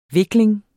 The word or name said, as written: vikling